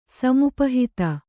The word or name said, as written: ಸಮುಪಹಿತ